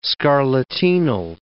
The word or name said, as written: scarlatinal